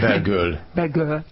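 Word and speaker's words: Bellegueule: Hogy kell kiejteni? Bocsánat. – Belgöl. – Belgöl, mint szép pompa, tehát ez egy beszélő név.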